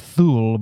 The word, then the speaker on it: Thule: It's spelled T-H-U-L-E. So you look at it and think it's Thule, but it's Thule Air Base, which has actually been there since World War II.